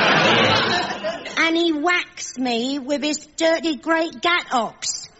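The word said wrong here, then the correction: gâteaux: Oh, you mean ghetto, ghetto. It may look as if it should be pronounced ox, but in fact, one says oh.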